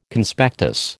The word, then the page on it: Conspectus: American English